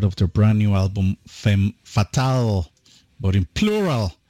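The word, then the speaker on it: Femmes Fatales: Well, you know, in French, you don't pronounce the S, so that's why I pronounce it exactly the same way. Femme Fatale and then Femme Fatale.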